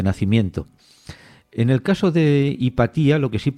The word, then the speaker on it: Hipatia: Bueno, hay que decir que algunos pronuncian Hipatia, otros Hipatia. La pronunciación más correcta sería Hipatia, con acento en la I, si nos basamos en el original griego.